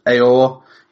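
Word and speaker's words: Aouar: I think his surname's pronounced Aor.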